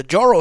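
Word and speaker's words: Jorō: I'm moving out of the United States because apparently now the Joro spider, or maybe it's a soft J, Joro spider, it's a giant venomous flying spider and it's spreading across the East Coast.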